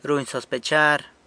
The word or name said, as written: runy sospechar